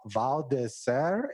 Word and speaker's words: Val d'Isère: I mean, I was checking out your website and I saw some places listed like the Val d'Isère in France.